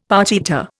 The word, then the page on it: partita: Indian